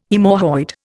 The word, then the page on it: haemorrhoid: Indian